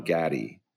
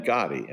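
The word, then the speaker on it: Gotti: And he said, the guy you got to look at is John Gatti. He didn't even pronounce it right. He meant Gatti.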